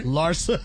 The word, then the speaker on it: Larsa: Larsa. That's how you say it.